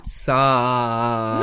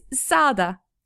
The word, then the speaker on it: sağda: Sağda. – No, not that long. – Okay, you say it. – It's sağda. – Okay. – Not sağda.